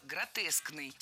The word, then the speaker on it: гротескный: Кстати, если мы хотим образовать от существительного «гротеск» прилагательное, то предпочтительным вариантом будет слово «гротескный» с однозначным ударением на втором слоге. Вариант «гротесковый» тоже допустим, но ударение на втором слоге сохраняется. Гротеск, гротескный, гротесковый.